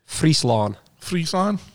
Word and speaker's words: Friesland: How do you say it? – Friesland, there you go.